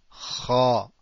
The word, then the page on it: خ: Cha